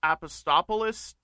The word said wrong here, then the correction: Apostolopoulos: Do I have his name? – Apostolopoulos. – I was close enough. I'll take that.